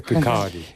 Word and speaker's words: Kirkcaldy: pronunciado en Kirkcaldy, en Escocia, en 2005, el discurso de Greenspan. No, pronuncia Kirkcaldy.